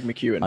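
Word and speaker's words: McKeown: He did an episode with Gregory, I don't want to say his name wrong, is it McCowan? That's the one. – Greg McEwen. – I know, I've pronounced it wrong. Greg McEwen, yeah.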